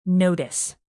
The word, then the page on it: notice: verb